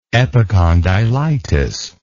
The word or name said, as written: epicondylitis